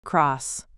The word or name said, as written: cross